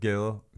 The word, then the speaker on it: gade: G-A-D-E. Does that mean street in Danish? – Yes, yes, definitely. – But the weird way they pronounce Gade, to me, they'd say Hamletsgade. So it sounded like Gil. Instead of Gade or Gade or Gade, they'd say Gil. So G-A-D-E.